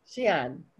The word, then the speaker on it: Xi'an: So it's Xi'an. – Xi'an. – Yeah. Xi'an. So I remember a group of Chinese. So it's the owner is from this little village outside. He's from Xi'an and he grew up in this little village that's outside.